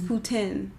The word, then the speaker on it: poutine: And I was told it's poutine. That's how they say it.